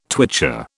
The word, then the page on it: twitcher: British English